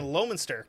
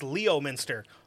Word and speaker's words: Leominster: We have a fucking Lowminster. – Okay? Yeah. – Which, if you look at it, is Leominster. That's not how you pronounce it, because you'll get stabbed if you pronounce it that way there.